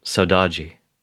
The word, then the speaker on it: Saudade: I'll try to do my best to pronounce the Portuguese. Your album, Saudade. How's that? – That's great, I think.